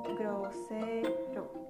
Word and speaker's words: grosero: In Spanish, the word for saying that correctly is grosero, grosero.